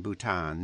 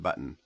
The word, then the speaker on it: Bhutan: This Trump called Nepal and Bhutan nipple and button in intelligence briefing.